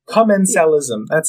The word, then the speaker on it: commensalism: There's also commensalism. That's how you pronounce that.